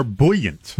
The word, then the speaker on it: buoyant: The markets are buoyant. – What? Am I saying that right? – Fantastic. Yeah, close enough.